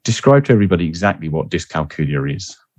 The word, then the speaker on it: dyscalculia: The right pronunciation is dyscalculia.